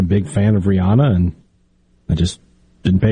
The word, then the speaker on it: Rihanna: Have we changed the pronunciation it's Rihanna.